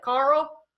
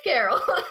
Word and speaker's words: Caril: Fun fact, Carol is spelled C-A-R-I-L. And when I started reading it, I hadn't watched any videos yet, and I was, like, pronouncing it like Rick from The Walking Dead, like, Carl? That's not how you say it. Fun fact, it's Carol.